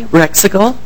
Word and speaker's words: Rexigal: My name is Carrie Rexigal, and I'll pause for a pronunciation guide. It sounds a little like bicycle.